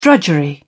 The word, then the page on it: drudgery: British English